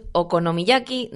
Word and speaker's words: okonomiyaki: Otra experiencia, el auténtico, a ver si lo digo bien, okonomiyaki de Hiroshima. – Correcto, eso es.